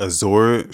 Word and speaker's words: Azure: It's A-Z-U-R-E, right? – Azure! – Yeah. – Mary Jo Foley says it's Azure. It's fucking Azure.